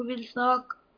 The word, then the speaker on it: بالساق: لا تنطق هذه اللام هنا. – والتفت الساق بالساق. – لا اسمعها يا شيخ. والتفت الساق بالساق. ترى نفس الساق الأول هي الثانية. اللام هنا نفسها هنا تدغم.